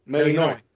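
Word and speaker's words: Melinoë: ¿Cómo se pronuncia eso? – Entiendo que lo pronunciaste bien. – Melinoe.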